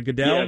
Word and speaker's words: Goodell: Do you know how I say his name? Is it Goodell? – Yes, that's correct.